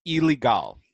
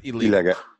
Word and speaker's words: Ilegal: So we'll so it is pronounced Ilegal, not illegal. – Ilegal, yeah. There's Ilegal. Yeah, Skip Martin was pretty hilarious with that. Everybody's like, where's the other L?